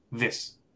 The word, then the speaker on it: this: Hablemos de la diferencia de pronunciación entre this and these. ¿Qué nos puedes decir? Pues a mí me gusta explicarlo como que this es más, es más corto, ¿no? This. Mientras que these es más largo, ¿no?